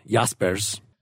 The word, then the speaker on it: Jaspers: Did we ever figure out how to pronounce that? – That is correct. You just did it right. Jaspers.